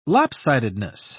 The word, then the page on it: lopsidedness: British English